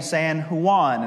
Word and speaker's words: San Juan: And he kind of let it slide, and he said, now, fellas, you probably should know that out there, they don't pronounce those things that way. They make their J's into an H sound, so it's San Jose and San Juan.